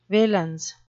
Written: valence

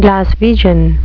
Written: glaswegian

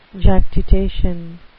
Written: jactitation

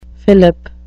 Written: fillip

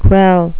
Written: kvell

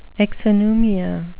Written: exonumia

